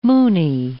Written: moony

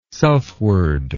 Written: selfward

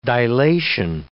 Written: dilation

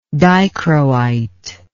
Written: dichroite

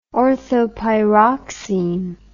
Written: orthopyroxene

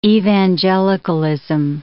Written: Evangelicalism

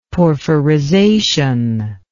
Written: porphyrization

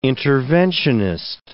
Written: interventionist

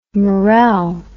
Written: morelle

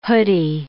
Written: hoodie

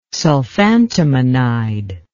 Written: sulfantimonide

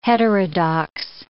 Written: heterodox

